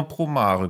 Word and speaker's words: Promare: Sagt man Promare oder Promare? – Also ich habe immer Promare gesagt, das ging ja.